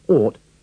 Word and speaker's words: ought: In the first four sentences, remember to pronounce the OU of ought with the sound of the double O in door. The G and the H are silent.